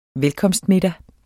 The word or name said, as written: velkomsttale